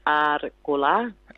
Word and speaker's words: Arrkula: those double R's are rolled, so it's Arrkula.